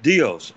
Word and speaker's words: Dios: Dios. – Dios, right? Like you said, you said it right, Dios.